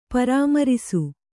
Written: ಪರಾಮ್ಮರಿಸು

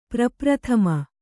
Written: ಪ್ರಪ್ರಥಮ

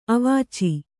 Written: ಅವೀಚಿ

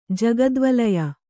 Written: ಜಗದ್ವಲಯ

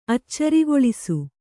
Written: ಅಚ್ಚರಿಗೊಳಿಸು

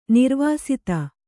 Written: ನಿರ್ವಾಸಿತ

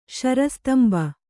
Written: ಶರಸ್ತಂಬ

